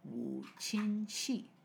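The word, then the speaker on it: 五禽戏: How do you say it again? Wu xin qi. – Wu. Wu. Qin. – Qin. – Xi. – Wu qin xi.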